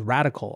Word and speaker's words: Radkl: He's investing in an individual capacity in a firm called Radical, although it's spelled R-A-D-K-L.